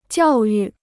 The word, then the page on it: 教育: jiào yù